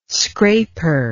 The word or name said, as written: scraper